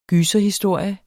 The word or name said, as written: gyserhistorie